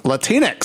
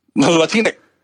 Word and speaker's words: Latinx: Latinx. Mm-hmm. Rhymes with Kleenex. – Latinx.